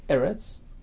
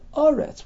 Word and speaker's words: Eretz: The Ran learns, the word Eretz can be pronounced Eretz or Aretz with a kametz, depending on where in a pasuk it is situated. At the end of a statement, it's Aretz. In the middle, it's Eretz.